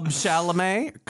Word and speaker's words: Chalamet: Club Chalamet. How do you say it? – You got it.